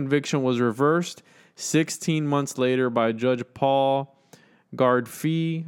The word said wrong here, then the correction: Gardephe: Gar- it was, Gardephe.